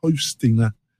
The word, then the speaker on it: Hostinger: Hostinger.